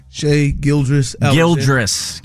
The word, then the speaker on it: Shai Gilgeous-Alexander: Shai Gilgeous-Alexander.